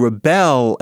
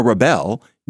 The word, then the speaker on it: rebel: Rebel, accent on the first syllable, is a noun.